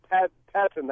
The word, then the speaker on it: Pettine: I was reading up on Pettine.